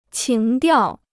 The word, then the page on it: 情调: qíng diào